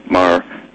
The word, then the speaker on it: Maher: I think it's Maher. I should actually pronounce it Maher or Maher. It's Bill Maher, it's pronounced.